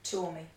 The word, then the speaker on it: Tuomi: Tuomi.